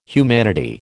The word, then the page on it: humanity: American English